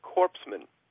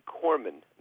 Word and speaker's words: corpsman: They're playing these tapes of a speech President Obama made recently praising some military people, in which he continually used the word corpsman. And, of course, although the word has a P in it, the P is silent, and it's you're a Navy corpsman, not a corpsman.